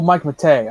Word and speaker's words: Matei: Oh, Mike Matei. Oh, okay. Mike Matei. Yeah, oh, yeah, it's, Mike Matei. I said the name wrong.